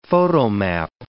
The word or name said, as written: photomap